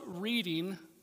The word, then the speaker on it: Reading: We're kind of slightly outside of Boston in the Stoneham and Reading area, which is spelled Reading, but you pronounce it Reading, okay?